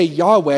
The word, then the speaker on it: Yahweh: So the way you would say Yahweh, you can't use it with your tongue, can't do it with your lips closed, you would breathe in Yah, and you would breathe out Weh.